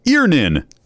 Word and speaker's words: Earnin: E-A-R-N-I-N is pronounced Earnin. I don't know how else you would pronounce it, but they really want it clear that that's how to pronounce it.